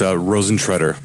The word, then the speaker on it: Rosentreter: It's Rosentreter.